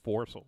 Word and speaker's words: forecastle: Foresel. It's spelled forecastle, but it says foresel.